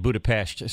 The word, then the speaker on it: Budapest: Well, it's interesting, because I have a feeling you've been there before, though, because you pronounced it correctly, and I didn't. You said Budapest. So I'm thinking you're quite the traveler and probably have already been to Hungary. – Quite the traveler. You're so cute. Well, because that's the way you pronounce it.